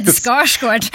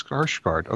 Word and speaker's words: Skarsgård: Oh, good, Skarsgård. – I thought it was Skarsgård, you know, because I'm very American, and apparently it's Skarsgård.